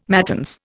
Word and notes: matins: Indian

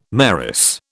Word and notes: mayoress: British English

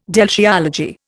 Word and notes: deltiology: Indian

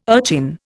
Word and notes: urchin: Indian

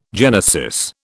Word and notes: genesis: British English

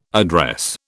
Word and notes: address: British English

